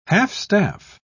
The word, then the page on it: half-staff: British English